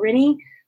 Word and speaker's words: Rinne: My name is April Rinne. Last name is spelled R-I-N-N-E.